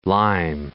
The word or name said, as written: Newcastle-under-Lyme